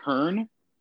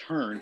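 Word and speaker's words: Hearne: like Hearn, but there's an e at the end, but you don't pronounce the e, so it's just Hearn,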